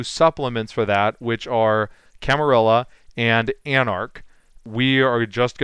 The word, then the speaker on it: Camarilla: We are here today to talk about Camarilla, so let me preface it by saying I've been pronouncing it Camarilla for like 25 years now, so I know that there are people out there who firmly believe that it should be pronounced Camarilla, but I just can't do it, guys, so it's going to be Camarilla out of me. Craig will have the option to pronounce it however he wants, but I just can't do the Spanish L's.